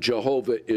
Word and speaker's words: Jehovah: And Jehovah is probably the best pronunciation of it.